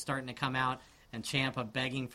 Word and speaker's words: Ciampa: But it didn't help him beat Ciampa at all. – No. – Ciampa, I'm sorry. We all know how to say it now. It's Ciampa.